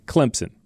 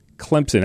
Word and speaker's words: Clemson: there's a P in Clemson. You don't see it. You don't spell it that way, but you'll get it right if you just spell it C-L-E-M-P-S-I-N, Clemson. Correct. That is the correct way to pronounce it. A lot of people want to put a Z in there. They want to say Clemson. No, not like crimson.